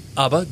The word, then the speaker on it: Ubba: Ubba, good.